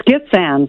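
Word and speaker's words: Gitxsan: It's Gitxgan, but that's you were pretty close. – I was pretty close. The X threw me off.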